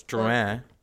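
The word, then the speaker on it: Drouhin: Well, it's Drouin, and then it's Bourgogne. But it's okay. Here's the deal. – Bourgogne? – Yeah. Here's the deal. – Like G-U-N? – Closer to that than gone or yeah.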